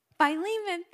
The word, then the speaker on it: Philemon: And oh, I meant to ask how to say this word before I started today, but I think it's Philemon. Is that how you say that? I don't know. Philemon 1:6. how do you say it? Philemon, thank you.